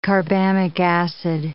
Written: carbamic acid

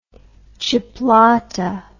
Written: chipolata

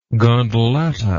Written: gondoletta

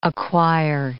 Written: acquire

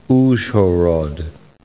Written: Uzhhorod

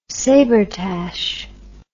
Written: sabretache

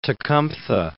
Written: Tecumtha

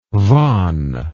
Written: von

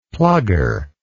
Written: plugger

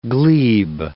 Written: glebe

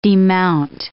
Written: demount

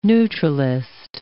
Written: neutralist

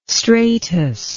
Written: stratus

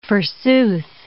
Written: forsooth

